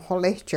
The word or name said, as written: xolisch